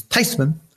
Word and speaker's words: Theismann: She says, the correct pronunciation is Theismann. And actually what they want to do is closer to it than what we have now.